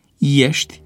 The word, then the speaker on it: ești: Astfel se rostesc cu IE, la inițială, pronumele personale: eu, el, ei, ele și formele verbului a fi: ești, erați, e, eram, erai, era, erați, erau. Greșeala de pronunțare cu E deschis în loc de IE se datorează probabil unor tendințe de hipercorectitudine,